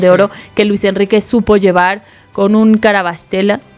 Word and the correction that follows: Kvaratskhelia: se dice así, ¿no? – Kvaratskhelia también, sí, espectacular, ¿no?